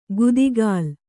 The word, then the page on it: ಗುದಿಗಾಲ್: gudigāl